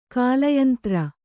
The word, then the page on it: ಕಾಲಯಂತ್ರ: kālayantra